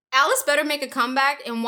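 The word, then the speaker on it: Alis: Yeah, it's A-L-I-S, Alice. – Alice. – And that's exactly how it's pronounced.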